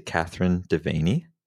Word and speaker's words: Kathryn Devaney: So I'm here talking today to Kathryn Devaney.